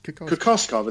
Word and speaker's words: Kokoskov: Kokoskov, isn't it? – Kokoskov.